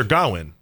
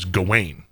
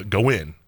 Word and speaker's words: Gawain: And Sir Gawain is how it's pronounced. I've only read it before, so I always thought it was Gawain. – Mm. – But everyone in this film pronounces it Gawain, so I'm going with them.